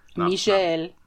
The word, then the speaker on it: Michel: I think I mispronounced his name. It's Michel Gondry. He's French, so... – Michel.